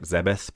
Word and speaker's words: Zebes: In the actual in-game text, it's pronounced like Zebeth, but I think it might be yes. kind of Aerith, Aerith thing. – From yes, Final Fantasy I think VII, that's correct. – Romanization yeah. and, yeah, I think it's kind of sort of probably been corrected throughout as Zebes, I think.